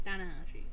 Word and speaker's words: Tanahashi: And for the interim AEW World Championship, we have Jon Moxley taking on Hiroshi Tanahashi.